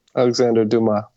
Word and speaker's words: Alexandre Dumas: Alexandre Dumas.